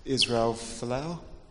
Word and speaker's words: Israel Folau: I was just wondering if Israel Folau, that's how you say it, he could have done with a group.